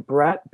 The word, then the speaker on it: Brett: And it's just my name, Brett, B-R-E-T-T. Hobel, H-O-E-B-E-L.